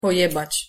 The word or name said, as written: pojebac